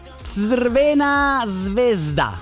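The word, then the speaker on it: Crvena zvezda: Allora, si dice Svena Svezda, sarebbe la pronuncia.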